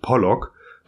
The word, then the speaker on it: Pollock: Pollack heißt eigentlich Pollock doch in der amerikanischen Aussprache klingen beide Namen nahezu identisch.